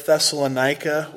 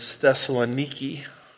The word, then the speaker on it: Thessaloniki: They pronounce it as Thessaloniki, which is always disappointing, learning you've been mispronouncing a word your whole life.